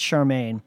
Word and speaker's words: Charmian: And Charmaine is spelled C-H-A-R-M-I-A-N, but pronounced Charmaine,